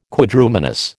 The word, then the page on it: Quadrumanous: American English